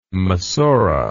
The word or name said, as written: massorah